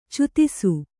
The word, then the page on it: ಛೇದಿಸು: chēdisu